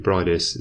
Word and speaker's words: Briedis: I apologise if I'm pronouncing Breedis wrong, by the way. I'm pretty sure it's supposed to be Breedis. It's just I've been living in Germany, so when I read it... when I read IE now, it just automatically comes out as E.